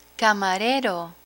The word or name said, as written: camarero